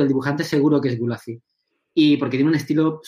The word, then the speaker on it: Gulacy: Entonces, em, bueno, pues Gulazi, que se escribe así, Gulazi, G-U-L-A-C-Y,